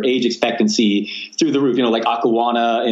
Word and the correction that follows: Okinawa: in Japan, if I said that wrong. And then there's a place Okinawa. – In California. – Okinawa.